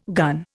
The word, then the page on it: gun: Indian